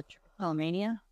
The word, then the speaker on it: trichotillomania: like I said, it might have been the start of the trichotillomania. – Yeah. That's how you say it. Trichotillomania. It is. It's one way they put it anyway.